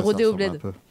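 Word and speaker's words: Rodeo BLD: mais du coup, bah, là, pour le coup, c'est à Nice, c'est bah, Rodeo Bled. – Rodeo Bled. – Ouais, Rodeo Bled est vraiment pas mal. Et c'est BLD. BLD, ça s'écrit pas Bled, mais...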